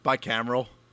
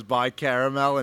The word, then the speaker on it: bicameral: Too bicaramel. – What? – Bicameral. – Oh, yeah. – I famously mispronounced it as bicaramel in high school.